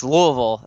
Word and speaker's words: Louisville: moving down here, I'd always call it Louisville, and everybody gave me all the kind of crap for it here, and I'm like, okay, I have to pronounce it right to be able to live here. So it's Louisville. They like to say Louisville.